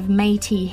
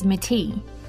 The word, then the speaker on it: Métis: During prison, he realised that he was of Métis heritage, which, side note, I pronounced incorrectly as Métis.